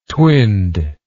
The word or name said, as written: twinned